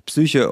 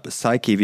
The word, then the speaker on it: Psyche: Alleine der Asteroid 16 Psyche oder Psyche, wie er auf Englisch ausgesprochen wird, soll Metalle im unvorstellbaren Wert von 10 Trillionen US-Dollar bergen.